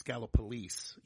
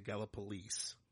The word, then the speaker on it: Gallipolis: And there's a town in Ohio, and if you read it, if you just read it, it would say, I know how to say it properly, so I'm going to try to say it wrong so you get it, but it would be like Gallipolis, or it would be Gallipolis is how it reads, but it's Gallipolis. You have to say Gallipolis.